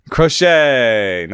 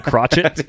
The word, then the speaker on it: Crochet: How do you say the guy's name that sits 100 from the left side? – Garrett Crochet. – Crochet. Nice. Better than the other one, which rhymes with rocket.